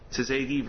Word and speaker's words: Tsegay: Oh, it's pronounced, it's spelled T-S-E-G-A-Y. Tsegi versus Gonzalez, Your Honor.